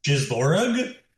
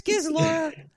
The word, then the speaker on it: Gizlora: Thank you, Gizlora. – It's a hard G, even on this planet. – Yes, it's a very hard G. – Oh, boy. – Gizlorug? – No. It's Gizlora.